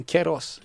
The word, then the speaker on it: Queiroz: Dels är det ju den gamla United-tränaren då, Portugal förbundskaptenen Carlos, uttalar man Queiroz.